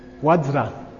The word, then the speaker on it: Vajra: In Sanskrit, Vajra. And that is the correct pronunciation. And please don't rewrite the sadhanas to put Vajra. Okay. It's Vajra. Okay. And that's correct.